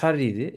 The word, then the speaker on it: Jarry: E, birisi Nicolas Harry'ydi. E, Jarry bu arada Jarry diye okunuyormuş.